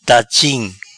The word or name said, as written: Dachim